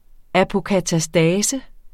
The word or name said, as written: apokatastasis